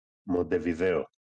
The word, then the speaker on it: Μοντεβιδέο: λοιπόν, καλησπέρα στην παρέα, λέει, από το Μοντεβίδεο της Ελλάδας στην Καλαμάτα. – Μοντεβιδέο. – Να, τονίζει, να τονίζει σωστά ο φίλος εδώ ο Νόσχαν.